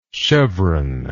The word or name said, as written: chevroned